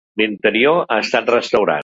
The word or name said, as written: restaurat